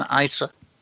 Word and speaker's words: ICER: Do we call it ICER? Is that that's perfect pronunciation, ICER.